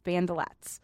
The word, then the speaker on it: bandelettes: So bandolette is spelled B-A-N-D-E-L-E-T-T-E-S, bandolettes.